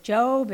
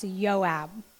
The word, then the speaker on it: Joab: I did look that one up because I was like, is it Job? Is it Joab? It is pronounced Joab, and he's a big shot in David's army.